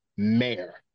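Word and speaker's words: Mär: The abbreviation is M-A-R, pronounced by a lot of people as Mare. – M-A-R with two little dots on top of the A.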